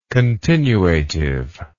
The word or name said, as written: continuative